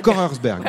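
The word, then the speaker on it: Kochersberg: que je vous encourage à prononcer. – Korersberg. Korersberg. Voilà, très bien.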